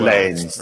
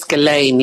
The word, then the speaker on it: Ghislaine: I can never say her name. Ghislaine Maxwell. – Ghislaine. – Right? Yeah, apparently it's Ghislaine, yeah.